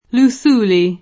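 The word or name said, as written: Luthuli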